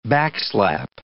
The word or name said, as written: backslap